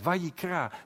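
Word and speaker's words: Vayikra (ויקרא): In het Hebreeuws heet dit boek Vayikra. Zo spreek je het uit, Vayikra.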